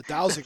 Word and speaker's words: dowsing: We had the downing rods. Hey, I finally got it right. – Finally, we had trouble pronouncing the dowsing rods that night. So the dowsing rods.